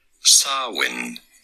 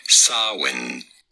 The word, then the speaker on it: Samhain: How do you go about pronouncing it? Sarwin. You don't want to stress on the first syllable, the sa syllable. The M in the middle, well, is kind of silent. Do not worry about it. Just say Sarwin.